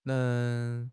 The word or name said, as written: Neng2